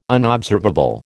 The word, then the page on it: unobservable: American English